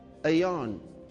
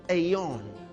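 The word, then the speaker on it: aion: You see, aeon, this age.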